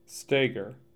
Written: Steger

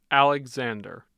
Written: alexander

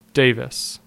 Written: Davis

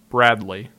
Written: Bradley

